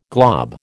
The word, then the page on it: glob: American English